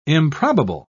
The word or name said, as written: improbable